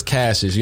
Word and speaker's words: Cassius: I need to do like, it's not pronounced Cassius, Cassis, or however they be saying it. Like, man, it's Cassius.